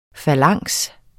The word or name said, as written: falanks